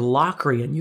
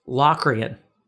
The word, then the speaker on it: Locrian: And then seven, diminished, is Locrian or Locrian. You can pronounce it either way. Now, I can't even name you a song really that emphasizes Locrian or Locrian.